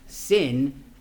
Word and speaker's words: Sin: Actually, pronounce it like my mom would actually pronounce sin, because she speaks Spanish, not English. She would say sin, right? That's how the pronunciation is there.